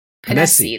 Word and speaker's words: Ponesse: Ponesse.